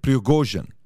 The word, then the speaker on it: Prigozhin: Okay, Prigozhin.